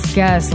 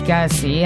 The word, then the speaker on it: Kelce: Nos, Taylor Swift és Travis Kelce, vagy Kelce, hát én akár kivel találkozom, aki amerikai mondjuk a TikTokon és kiejti a nevét, mindig kétféleképpen mondják, hogy Kelce vagy Kelce, nekünk teljesen mindegy, az a lényeg, hogy hivatalosan is összekötötték az életüket.